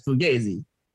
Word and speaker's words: Fugazi: Fugazi, I'm just saying it wrong. It's Fugazi.